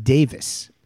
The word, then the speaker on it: Davies: And pronounced in England they pronounce it Davis.